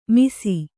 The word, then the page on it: ಮಿಸಿ: misi